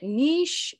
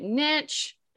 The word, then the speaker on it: niche: But before we dive into our discussion, I just want to ask you, how is it pronounced? Is it niche? Is it niche? – I love this question. I get it a lot, which won't surprise anyone. So according to the dictionary, both are right.